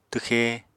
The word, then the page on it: twxe: apatani